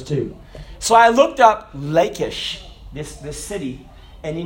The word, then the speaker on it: Lachish: It's Lachish, but I think it's pronounced Lachish in Hebrew.